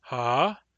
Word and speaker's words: ha: In every dialect, the word for yes, ha, has that rising intonation. It's a long, low intonation followed by a rise.